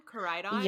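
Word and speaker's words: Koraidon: Like Rhydon, Caridion.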